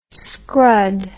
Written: scrud

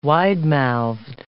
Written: widemouthed

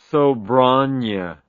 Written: sobranje

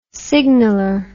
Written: signaler

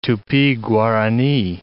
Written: Tupi-Guarani

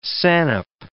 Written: sannup

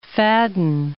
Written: Fadden

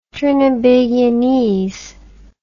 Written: trinibagianese

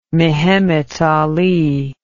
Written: mehemet ali

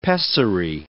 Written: pessary

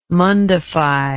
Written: mundify

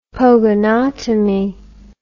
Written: pogonotomy

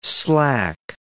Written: slack

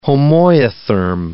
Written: homoiotherm

